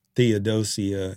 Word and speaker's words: Theodosia: Right away, I want to know who the Theodosia, is that how you say it? Uh-huh.